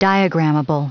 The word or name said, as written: diagrammable